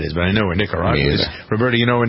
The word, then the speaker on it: Nicaragua: Nicaragua.